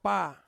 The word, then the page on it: pa: Ansus